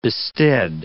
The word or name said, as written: bested